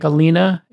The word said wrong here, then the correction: Alinea: am I pronouncing it correctly, in Chicago? – Alinea.